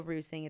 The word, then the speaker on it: Reusing: You can follow me on Instagram, which is my first and last name, Kendall Rusing. It's spelled like reusing.